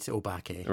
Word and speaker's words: obake: And there's an obake. You'll have to correct me on my pronunciation here. – That's about right, obake.